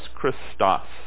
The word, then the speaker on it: Christos: For our purposes, it's C-R-I-S-T-O-S. Christos is the way it's pronounced. Actually, not the letter C, but the letter X in their language is the one that makes that C sound.